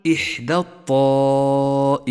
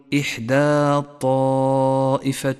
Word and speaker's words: إحدى الطائفتين: لاحظ كيف سقط حرف الألف من اللفظ في كلمة إحدى، إحدى الطائفتين، سقط من اللفظ لأنه حرف ساكن واللام التي بعده ساكنة، اجتمع ساكنان فسقط الأول من اللفظ، فقل إحدى الطائفتين ولا تقل إحدى الطائفتين، فهو خطأ.